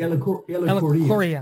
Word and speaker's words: Ellacuria: Father Aloysius Agoria. How do I pronounce the last name? – Elicoria.